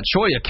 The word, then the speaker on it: Cholla: Cholla cactus. I would weigh. How did I say that? It's that double L. Makes the. Yeah. Cholla cactus spines.